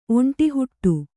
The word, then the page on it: ಒಂಡೆಹಿಡಿ: Kannada